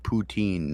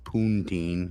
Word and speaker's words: poutine: Putin, which means big slut. – And look how that's spelled, Bonehead. It's poutine, not poontine, like poontine, you just said.